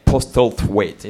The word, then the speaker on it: Postlethwaite: Like the father of Perseus is this actor whom I love, called Pete Postlethwaite. It's very hard to pronounce for a Frenchman. Postlethwaite. It's a TH in the middle.